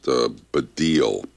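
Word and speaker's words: Baddiel: It is pronounced Badil. Badil Center and the Lightning Seeds, Three Lions.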